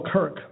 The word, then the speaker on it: Kirk: Brother Curtis, is that how you say your name? Kurt, without the is. Kurt. – K-R-K. – Oh, Kirk, okay.